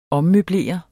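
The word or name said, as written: OMMØBLERE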